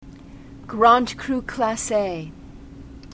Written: grand cru classe

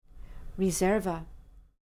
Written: riserva

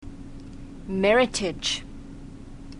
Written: meritage

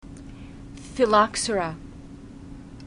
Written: phylloxera

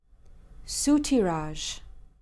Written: soutirage

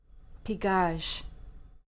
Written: pigeage

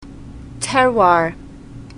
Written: terroir

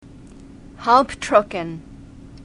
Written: halbtrocken

